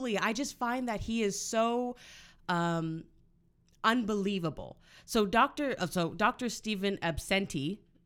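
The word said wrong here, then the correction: Abnesti: am I saying it right? – No. – Abnesty. Abnesty. It's just such a stupid name.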